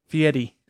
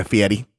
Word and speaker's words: Fieri: Fieri. – Yeah, Fieri.